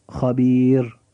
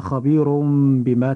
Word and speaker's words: خبير: لاحظ معي أخي المسلم أن حرف الراء هنا يرقق حال الوقف فقط، أما إذا وصلنا الكلمات ببعضها فلا ترقق الراء عند النطق بها، كأن نقول مثلا إن الله خبير بما تعملون، فنجد أن كلمة خبير لم نقف عندها وإنما وصلنا الكلام فكنا خبير بما، فحرف الراء في نطقنا له عند هذه الحالة يجب ألا يرقق مثل الوقف عليه.